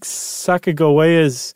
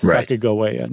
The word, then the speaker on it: Sacagawea: And not once do they spell that third syllable, G, with a J sound, with a J. It's always a G. – Okay. – And they think that it was a hard G, so that it's Sacagawea, not Sacagawea.